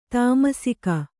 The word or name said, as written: ತಾಮಸಿಕ